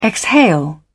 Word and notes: exhale: British English

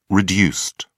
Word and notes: reduced: British English

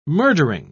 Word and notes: murdering: British English

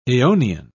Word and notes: eonian: British English

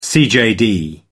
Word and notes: cjd: American English

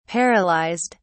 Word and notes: paralyzed: British English

